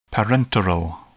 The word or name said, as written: parenteral